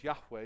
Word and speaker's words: Yahweh: We pronounce it as Yahweh because that's the best we can affix to a pronunciation.